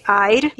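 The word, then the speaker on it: Eire: esse Aire, né? Que se escreve como até hoje se escreve o nome da República da Irlanda, que é E-I-R-E, né? E se lê Aire.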